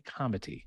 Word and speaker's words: comity: So second category is what we're calling Divine Comity. Now I'm pronouncing that with a soft T because it's comity with a T, as in getting along, as in playing well with others.